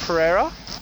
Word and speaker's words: Pereira: Pereira? – Yeah.